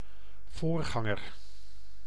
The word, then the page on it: pasteur: [pas.tœʁ]; France